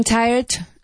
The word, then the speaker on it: tired: Careful of your pronunciation of tired. One syllable. – I'm getting tired of making lists.